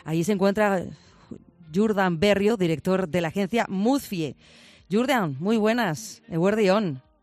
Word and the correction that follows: Mozfi: Oye, es Mozfi. – Eso te iba a preguntar, lo he dicho bien. Mozfi, oye, me sí, queda claro. Mozfi.